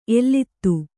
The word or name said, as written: ಎಲ್ಲಿತ್ತು